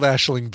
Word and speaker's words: Aisling: The only Aisling, part I could give was Aisling. B, but Aisling? A-I-S-L-I-N-G. That's a crazy way to pronounce that. That's amazing. – Oh, Claire is yelling in the chat room. It's pronounced like Brian said it.